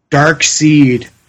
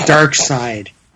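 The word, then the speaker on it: Darkseid: My 8-year-old pronounces this Dark Seed. I pick Dark Side.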